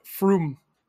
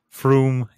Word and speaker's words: Frum: Well, then you have to pronounce it Frum. – Frum, yeah. – It's got an umlaut.